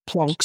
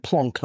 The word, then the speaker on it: Planck: Now, the smallest possible area, according to physics, that you can have is something called the Planck square. Mm-hmm. I use the term Planck, not Planck, because he's German.